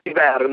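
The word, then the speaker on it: Cyberia: cioè la pronuncia è Siberia, no? Regione nordica, sì.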